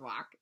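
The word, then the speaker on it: wrock: The W is silent. It's W rock. And it's... – It's walk? – It's rock.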